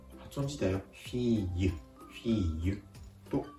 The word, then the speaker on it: fille: フィーユフィーユ